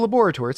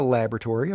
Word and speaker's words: laboratory: So this guy, he's going off to his laboratory, and yeah, it's a laboratory. It's not a laboratory, okay? There's an O in there. It's laboratory. – What's the difference? – It's just the British pronunciation.